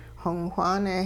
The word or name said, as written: xong whaane